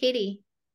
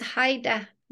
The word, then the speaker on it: Heide: My last name is H-E-I-D-E, and people call me Heidi. – I thought it was Heidi. – It's Haida, but the E is silent.